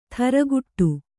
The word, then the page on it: ಥರಗುಟ್ಟು: tharaguṭṭu